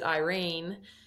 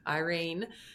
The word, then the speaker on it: Yrene: So the second part of the novella is the assassin and the healer and how she connects with, I pronounce it Irene, Y-R-E-N-E, Irene, in the tavern, who's actually a healer with, you know, an incredible story.